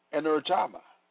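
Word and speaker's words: energema (ἐνέργημα): The Greek word for working is energema, pronounced energema, meaning operation, working, or active.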